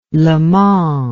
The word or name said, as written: le mans